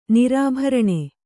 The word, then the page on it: ನಿರಾಭರಣೆ: nirābharaṇe